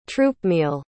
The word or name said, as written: troopmeal